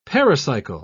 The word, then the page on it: pericycle: British English